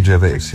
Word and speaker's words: Gervais: Ricky Gervais, ne? – Ricky Gervais, ja.